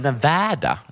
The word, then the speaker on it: Nevada: Det var lite märkligt, för det uttalas Nevada, egentligen.